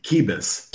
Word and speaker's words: Qubis: It's called Quibus. – Quibus, naturally. Yes, because they're from Wales or something, right? they don't say the U.